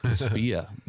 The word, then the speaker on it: SPIA: SPIA.